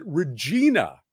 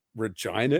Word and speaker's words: Regina: But the American editor, the American narrator said Regina, Saskatchewan, throughout, which is, of course, the standard pronunciation of that word is Regina. But Regina is the city, and it's wrong every single time in the audiobook.